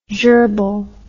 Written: jerbil

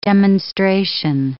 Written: demonstration

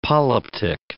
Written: polyptych

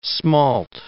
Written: smalt

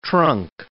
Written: trunk

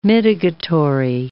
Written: mitigatory

